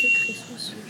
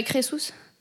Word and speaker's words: Crésus: Je connais que Crésus.